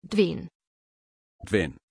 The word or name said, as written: Duane